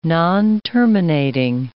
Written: nonterminating

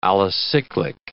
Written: alicyclic

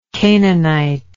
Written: canaanitic

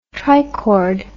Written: trichord

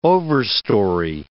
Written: overstory

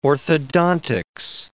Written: orthodontics

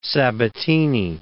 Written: Sabatini

